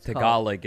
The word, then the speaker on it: Tagalog: Tagalog is how you say it.